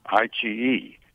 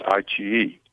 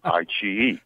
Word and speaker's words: iQIYI: And I said, iQIYI. And they said, no, it's iQIYI. And I said, okay, iQIYI.